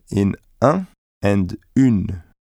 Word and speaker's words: une: Let the air go through your mouth only when pronouncing the vowel sound u in une, and do pronounce the n. – U. Une.